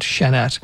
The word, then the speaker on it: Şener: Again, name is Emre Şener. Şener is spelled S-E-N-E-R. And if you're using the Turkish alphabet, S would have a small, tiny cedilla underneath it, which makes an S-H sound, Şener.